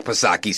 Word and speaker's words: Psaki: Oh, well, then I stand corrected, Jen Bercirclebeck Psaki, Silent P, of course, because then it's okay, right?